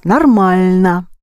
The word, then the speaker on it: нормально: Нормально. Now the telephone is working normally. – Сейчас телефон работает нормально. – Mm-hmm. Just pronounce нормально. – Нормально. – Yes, because or is not accented.